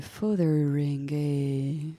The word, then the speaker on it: Fotheringay: Et euh, dans le hall du château de Fotheringay, c'est comme ça qu'on prononce Oui.